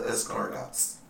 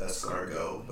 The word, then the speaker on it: escargot: You had all the escargots. – Gots? – The way it's spelled, you pronounce it escargot, but the way it's spelled looks like escargot. – All that. The T is silent.